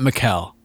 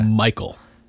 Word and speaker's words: Mikel: Not Mikkel. – Yeah. – Michael. – It's spelled M-I-K-E-L.